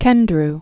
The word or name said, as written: John Cowdery Kendrew